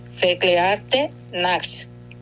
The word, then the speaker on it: Verklärte Nacht: Y esta pieza musical que les presenté, te voy a repetir el nombre tal como se lo consulté a un alemán para no equivocarme en la pronunciación, y espero hacerlo bien: Verklärte Nacht, que significa la noche transfigurada.